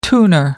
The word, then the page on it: tuner: American English